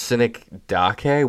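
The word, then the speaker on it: synecdoche: It looks like it's pronounced Cynic Dake, which is not better.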